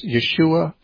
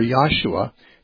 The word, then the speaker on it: Yeshua: So, yeah, Jesus would have been, his name would have either been pronounced Yeshua or Yahshua. Some people think Yahoshua.